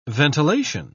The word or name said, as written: ventilation